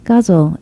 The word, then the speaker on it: ghazal: And before we begin, I just want to tell people that Guzzle is an Arabic love poem, and it is spelled G-H-A-Z-A-L.